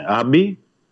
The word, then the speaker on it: Άμπι: Επίσης, εχθές ε, ανακοινώθηκε η απόκτηση του 20χρονου Άγγλου διεθνή, στόπερ με την ελπίδων Νέλσον Άμπι, έτσι όπως προφέρεται το, το όνομά του Άμπι, ε, οι δύο στόπερ και ε, έχει μπλοκάρει η περίπτωση του τρίτου στόπερ.